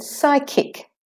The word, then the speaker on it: psychic: The next word, psychic. Both syllables are stressed. Psychic. The psi is slightly longer. Psychic. The ch is saying k, as in kitten, like in the word school. Psychic.